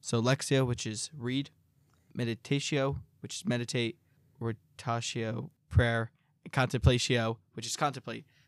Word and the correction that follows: oratio: Lectio, meditatio, oratio, and contemplatio.